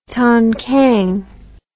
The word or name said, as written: tongkang